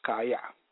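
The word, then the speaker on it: kaya (חיה): The Hebrew word quicken is pronounced kaya, meaning to live, revive, keep, make alive, let live, nourish up, preserve, quicken, recover, repair, restore to life, be whole.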